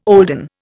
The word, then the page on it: olden: Indian